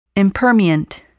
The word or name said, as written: impermeant